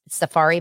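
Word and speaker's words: Safaree: It's Safari, but it's spelled differently. It's S-A-F-A-R-E-E, Love and Hip Hop Atlanta.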